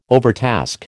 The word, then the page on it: overtask: American English